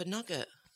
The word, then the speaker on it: nougat: For nugget. – Nugget's N-U-G-G-E-T. – No, it's not. It's spelt exactly the same. It's whether you're upstairs or downstairs and how you pronounce it.